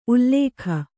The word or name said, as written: ಉಲಿಗ